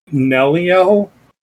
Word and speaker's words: Nelliel: Her name is, like, weird, but, like, it's pronounced, like, Nelliel.